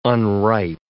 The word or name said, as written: unripe